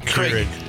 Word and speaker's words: Keurig: Johnny brought in a coffee maker because Krieg. – Keurig. – Keurig. – Keurig. Yeah. You don't even know how to say it.